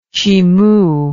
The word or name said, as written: chimu